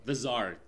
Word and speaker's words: czar: And it's, yeah, so, like for example, there's the stuff with the Tsar of the how do you say that word? The czar. Thank you. It's the Z.